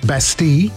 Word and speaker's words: Bastille: Okay, today is Bastille Day in France, national holiday, or to be correct pronunciation, Bastille.